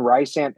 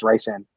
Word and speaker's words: Rhysand: And it's just for Lucien, Rhysand. And it's not even pronounced Rhysand. Sabrina pronounces it. Yeah, Sabrina pronounces it Rhysand.